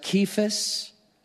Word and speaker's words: Cephas: Jesus changed his name and said, "Your name's going to be Cephas." That's the Hebrew version of Peter, but it's in Hebrew pronounced Kephas, and it literally means a hollow rock.